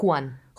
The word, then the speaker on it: Juan: A zase J sa vyslovuje ako Ch. Čiže keď vidíte niečo napísané z J, tak to vyslo- vyslovte ako Ch. Juan.